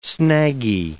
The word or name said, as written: snaggy